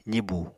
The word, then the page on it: zibu: apatani